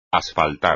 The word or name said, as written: asfaltar